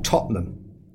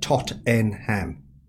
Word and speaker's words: Tottenham: Yeah, I come from a place in England that we call Tottenham. But the way it's spelt, all Americans call it Tottenham.